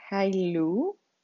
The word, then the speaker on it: Halu: El segundo símbolo es el Halu, H-A-L-U, que lo pronuncian Hailu, y significa amor, verdad y belleza.